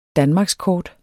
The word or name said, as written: danmarkskort